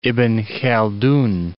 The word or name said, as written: Ibn-Khaldan